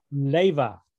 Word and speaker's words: Leva: Is that how you pronounce it? – Leva. – Leva. – Think of Princess Leia with a V.